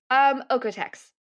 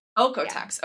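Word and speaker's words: Oeko-Tex: Is it Oikotex? Ecotex? How do you say that? – Oikotex. – Oikotex. Yeah. Okay, cool.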